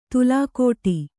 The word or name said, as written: ತುಲಾಕೋಟಿ